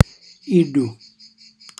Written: ìļļu

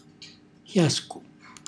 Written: xjascu

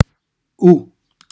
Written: u

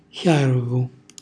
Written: xjarvu

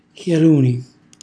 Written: xjaluni

